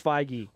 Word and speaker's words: Feige: They basically announced Kevin Feige, and it's pronounced Feige.